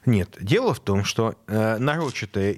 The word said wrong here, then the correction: нарочитое: использование феминитивов есть отличительная черта э, коверканье языка представителями ЛГБТ-сообщества. – Нарочитое правильное ударение. – Нарочитое. Вот. Это тоже правильно.